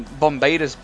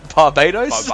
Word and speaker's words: Barbados: Because what's going to happen is you rock up on Bombardus Beach or wherever it is. – Yeah. – Is it Barbados? – I said that wrong. Bombardus.